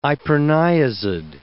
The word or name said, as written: iproniazid